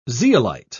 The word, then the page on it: zeolite: British English